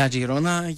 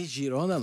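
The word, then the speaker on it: Girona: Ναι, η Αγιρώνα και ο Πετρίου, γνωστό μέρο τη Citigroup, η Αγιρώνα. – Μεγάλη και ομάδα. – Αχιρώνα. Εντάξει, Γιρώνα νομίζω ότι προφέρεται.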